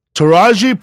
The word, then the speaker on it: Taraji: How do you say her name? Taraji P. Henson.